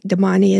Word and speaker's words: Damane: That's how I pronounce it, for what it's worth. – It's either that or Damane. I've heard it either way, but I think Damane is the probably the closest.